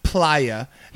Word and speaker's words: playa: The cold-hearted Playa. And he was our friend before. – Playa. – It's pronounced player? – Yeah, it's playa. Playa is Spanish for the beach.